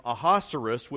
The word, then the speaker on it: Ahasuerus: But Esther will call him Ahasuerus, which rhymes with rhinoceros, the whole book.